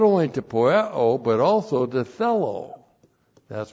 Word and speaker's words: poieo: And then we have the verb poieo, not only to do. P-O-I-E long O. Poieo, the verb to do.